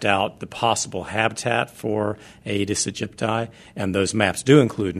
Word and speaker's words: Aedes aegypti: And in January, health officials were reporting that only one type of mosquito, I'm going to say this incorrectly, the Aedes aegypti, is that how you say it? – Mm-hmm. Very good.